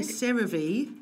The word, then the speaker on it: CeraVe: I've been using, I don't know how you pronounce it, I'm going to say CeraVe. – Yes, I think that's right.